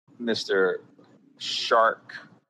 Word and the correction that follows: Chark: even though it's spelled C-H-A-R-K. Isn't it Shark, not Chark? – No, it's Chark. – It is Chark?